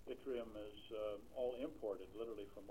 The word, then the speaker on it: yttrium: The development of yttrium, that's the correct pronunciation, a rare earth element, could revitalize the Ketchikan area after the loss of the pulp mill.